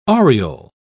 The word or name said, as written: aureole